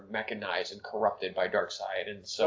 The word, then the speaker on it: Darkseid: So there was a recent Justice League movie where Barry had to do it once again because Darkseid takes, you know, Thanos. – It's spelled to look like seed, but it's really side. – Dark side, yeah.